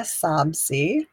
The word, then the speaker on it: Sobsey: Leah Sobsey, which actually does get butchered quite a bit.